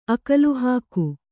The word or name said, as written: ಅಕಲುಹಾಕು